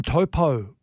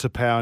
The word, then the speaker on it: Taupau: Uh, they've got um, Martin Taupau is the way that he wants to be pronounced now. – It's not Topo anymore.